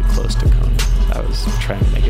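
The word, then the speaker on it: Connan: I think it's pronounced Conan. – No, it's actually Conan.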